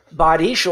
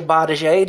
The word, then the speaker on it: bardiche: Bardiche or Bardiche, depending on how you like to pronounce it.